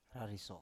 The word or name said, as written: rariso